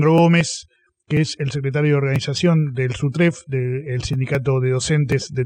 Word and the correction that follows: SUTEF: Esta vez lo dije bien, ¿no? – No, es SUTEF. – SUTEF. Ah, yo le agrego una R.